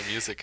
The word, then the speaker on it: Music: Yeah, it's definitely it sounds very Lorenzo music to me. That's how you pronounce it, right? Music? – That's how I've heard it pronounced.